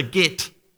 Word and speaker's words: get: That certificate was called a get, G-E-T, that's how you pronounce it.